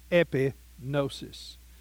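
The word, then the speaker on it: epignosis: E-P-I-G-N-O-S-I-S. E-P-I-G-N-O-S-I-S. Epinosis.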